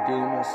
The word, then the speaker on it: Dumas: Amy Dumas is going to come back. Or Amy Duma, I think the S is silent, whatever.